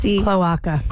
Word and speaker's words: cloaca: And females, I don't know how to pronounce it, C-L-O-A-C-A. – Cloaca. – Cloaca? – There you go.